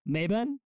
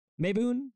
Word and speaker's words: Mabon: I'm going to pronounce the second, the autumn equinox, Thanksgiving as Mabon, Mabon, Mabon, M-A-B-O-N, Mabon.